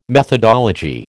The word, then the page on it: methodology: American English